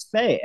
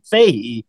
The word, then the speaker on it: Fahey: I was like, no, it's fuck, because I found out he pronounced it Fahey. I was like, no, fuck that, it's Faye. And then everybody else in the world pronounced it Fahey. And I was like, oh, I'm wrong.